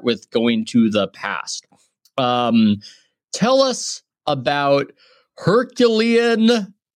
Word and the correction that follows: Herculaneum: how do I say it? Papyri. – Herculaneum papyri. Yeah.